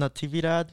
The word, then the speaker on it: Natividad: It's Christian Natividad. The way you spell Natividad, it's like Navidad, but add a T-I before the...